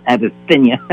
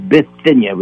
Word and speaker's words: Bithynia: It's not Abyssinia. This is Bithynia with a TH.